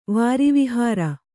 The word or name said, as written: ವಾರಿವಿಹಾರ